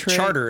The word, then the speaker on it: Chartres: No, that's Charter is how they pronounce it down there.